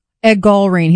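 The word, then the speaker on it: Gallrein: How do you say his name? It's Ed Gallarin. – Ed Gallarin. He's a captain. He's Gallarin.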